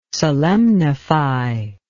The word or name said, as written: solemnify